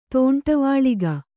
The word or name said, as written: ತೋಂಟವಾಳಿಗ